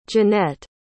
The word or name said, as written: jonette